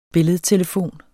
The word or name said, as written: billedtelefon